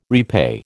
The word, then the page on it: repay: American English